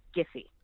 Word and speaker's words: Giphy: And then at some point it turned to GIF, so it is a totally valid question, but the hard G, it's Giphy.